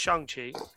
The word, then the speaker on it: Shang-Chi: It's Shang-Chi. But go see that too, guys. It's out this weekend. Check out our, if you're curious about the character, check out our Who is Shang-Chi episode.